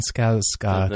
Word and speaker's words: Skarsgård: Stellan Skarsgård.